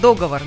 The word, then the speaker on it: договор: И вот самое популярное распространенное слово с неправильным ударением, да, это слово «договор». Многие, многие... – Договор. – Говорят «договор». Никаких договоров. – Договоров. – Да, только договоры. И сколько об этом слове не писали, да, и не говорили, все равно люди, большее количество людей ставят ударение неправильно. Некоторые считают, что разрешается произносить с ударением на первый слог, но это не так. Единственный способ правильного варианта с ударением на последний слог. Договор. И никак иначе, друзья.